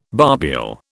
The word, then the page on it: barbule: British English